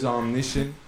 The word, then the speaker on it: Omniscient: My name is Omniscient. It's spelled like this, O-M-N-I-S-C-I-E-N-T.